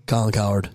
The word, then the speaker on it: Cowherd: Well, I think that going back to what Colin Cowherd said, did I get his name right? – Colin Cowherd. – Cowherd, yeah.